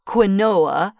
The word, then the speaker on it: quinoa: And quinoa is spelled Q-U-I-N-O-A. Some people pronounce it quinoa, but it's quinoa.